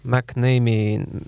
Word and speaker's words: McNamee: kuidas me hääldame teda? McNamee, McNamee mm.